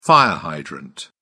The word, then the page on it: fire hydrant: British English